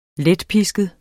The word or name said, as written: letpisket